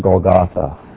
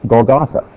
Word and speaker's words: Golgotha: Now, if we add the tav, the cross, to the word Gilgal, we get the word Golgolet, or in our Bibles, it's pronounced Golgotha. Okay? See the G-L? G, and there's also an L here I left out. There's an L there, L sound. And then you get the tav, gives us the T-H sound at the end.